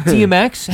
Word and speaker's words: DMX: Is it French? I don't think they pronounce the X. – No, it's not. – Okay, invite DMX. – Je ne sais quoi, bitch. – Yeah, how do you spell that? – D-M-X.